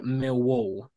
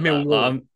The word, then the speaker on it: Millwall: Also, can I just say, I love how you're saying Millwall. Like Millwall. – That. I'm not saying it right. I'm saying Millwall, but yeah, I'm gonna start putting the emphasis on the wall like you do.